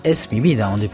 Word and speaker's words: Es mi vida: Es mi vida, on prononce comme ça.